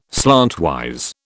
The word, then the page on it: slantwise: British English